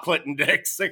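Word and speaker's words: Clinton-Dix: But listen, by the way I did it, it would be Clinton Dix. – Right. A little reverse there. – The H is silent.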